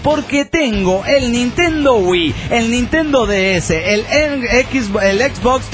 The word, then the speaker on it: Wii: Paz y amor y una carga de sueños. Bueno, queremos que lo pueda cumplir. – Sí, postscriptum. El Wii se pronuncia así, Wii, y no Y.